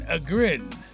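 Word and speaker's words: Ågren: V-I-S-H-N-E-V-A. Vishneva. That's just funny. They got a nice flow to it. Morgan Agren is celebrating a birthday today. A-G-R-E-N. That's how I'm saying it, because that's how you say it.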